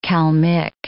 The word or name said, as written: Kalmyk